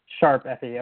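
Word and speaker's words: ♯FE: So Tokyo Mirage Sessions, hashtag FE, I forget, how do you pronounce Sharp. – It? – Sharp. – Sharp FE, okay.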